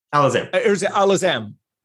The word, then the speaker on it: Alazzam: Did I say that correctly? – Alazzam.